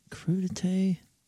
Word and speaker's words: crudité: Crudité. – Yeah. – Am I saying that right? Crudité. – Yeah.